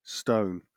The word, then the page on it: stone: British English